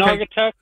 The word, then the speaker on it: Naugatuck: Say that right? What town are you from in Connecticut? – Yeah, you said it right. – Okay. – Naugatuck, Connecticut. – Gotcha. All right, just wanted to make sure I pronounced it right.